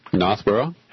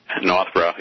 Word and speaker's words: Northborough: a few words like Northborough, my hometown was I always pronounced it without the R's in it, so Northborough. – Northborough, yeah.